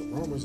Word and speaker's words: Rholmers: The Romers is something I pretty much started and named, and it's spelled R-H-O-L-M-E-R-S, Romers.